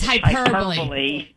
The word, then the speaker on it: hyperbole: Well, I just wanted to correct pronunciation of hyperbole. She said hyperbole. – Yeah, it's hyperbole. Yeah, when I read it out, and I was like, hyperbole, huh?